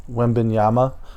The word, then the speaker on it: Wembanyama: It's clear Wembanyama. I've been calling him Wembanyana. I just realized that I've been saying his name wrong the whole time.